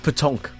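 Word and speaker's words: pétanque: I might not spell it right, but it's P-E-N-T-A-Q-U-E. – Pentacue. – Pentacue. – With an extra N. It's petanque. – There we go. – It's French. – Yeah. – Kaplunk. – Not kaplunk. Petanque.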